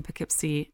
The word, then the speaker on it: Poughkeepsie: Let's see, they've got Kansas City, Missouri, and Poughkeepsie, New York.